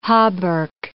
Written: hauberk

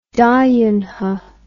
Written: dayunhe

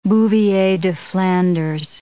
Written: Bouvier des Flandres

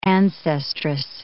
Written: ancestress

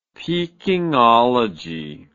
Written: pekingology

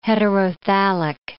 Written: heterothallic

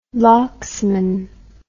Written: locksman

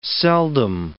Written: seldom

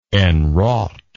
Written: enwrought

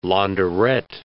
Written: launderette

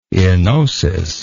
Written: enosist

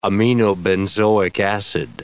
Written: aminobenzoic acid

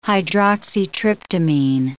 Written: hydroxytryptamine

